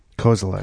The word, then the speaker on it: Kozelek: Kozelek.